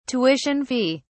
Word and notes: tuition fee: British English

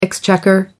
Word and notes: exchequer: American English

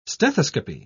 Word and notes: stethoscopy: British English

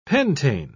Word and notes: pentane: British English

